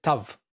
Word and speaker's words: Tav: It's a Hebrew character used for parameter name, and it's called Tav. It's pronounced as voiceless T.